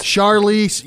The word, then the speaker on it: Charlize: Charlize.